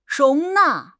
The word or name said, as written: 容纳